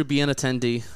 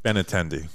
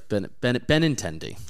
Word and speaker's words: Benintendi: Yeah, they got Andrew Benintendi. That's not how you say his name. – Benintendi. – Yeah, Benintendi.